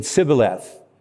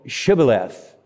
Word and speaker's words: Shibboleth: But the pronunciation, they couldn't pronounce the SHs, so they said Sibboleth. No, it, Shibboleth.